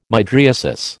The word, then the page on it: mydriasis: American English